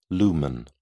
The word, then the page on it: lumen: British English